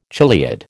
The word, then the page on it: chiliad: American English